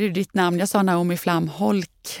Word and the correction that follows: Flamholc: men du sa Flamholls.